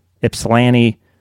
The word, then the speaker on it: Ypsilanti: Places like Ypsilanti, Livonia. – Mm-hmm. Good pronunciation there on Ypsilanti, by the way.